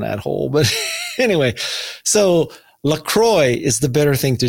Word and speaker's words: La Croix: LaCroix is the perfect French pronunciation of the word, but it is a Wisconsin brand based off of the LaCroix River.